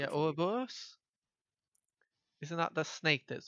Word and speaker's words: Ouroboros: He's releasing a role-playing game called Ouroboros. I'm not sure how to pronounce it. – Yes, anyway. – You did pronounce it correctly.